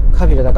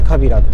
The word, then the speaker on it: 川平: ょっと前ってもうだいぶ前なんですかねあのキャスターやってましたよねスポーツの報道番組でねあのカビラのあのカビラ 1 ねに行ってきましたうんあのねカビラだから川に平と書いてねカビラって読む